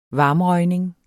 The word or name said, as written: varmrøgning